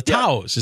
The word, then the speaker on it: Taos: Taos.